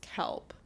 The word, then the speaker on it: KELP: It's also known as KELP because it's abbreviated CEOLP, and they pronounce it KELP.